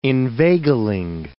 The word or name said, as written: inveigling